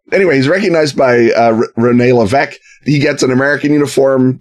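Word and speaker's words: Lévesque: Rene Levesque. You don't pronounce the S.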